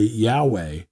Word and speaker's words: Yahweh: How do you pronounce this word? YHWH, well, then they put in a and an E in there so you can understand and say Yahweh.